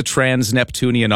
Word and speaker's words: trans-Neptunian: I want to ask right off the top, my very first question is, what is a trans-Neptunian object? And am I even saying that right? – Yeah, you're saying it right.